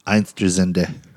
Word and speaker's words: Einstürzende: Einstürzende.